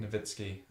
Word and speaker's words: Nowitzki: You added an extra N. – Novitsky. Oh, Novitsky, sorry. Novinsky. I thought you were going to say it's with the W, but you don't no. pronounce the W. Yeah, yeah. No, you do pronounce the W, but you pronounce an extra N. – No, but it should be a V sound because it's.